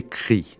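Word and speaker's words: Cree: Alors, cri, il faut prononcer cri. Ça s'écrit avec deux E, mais ça se prononce avec un I.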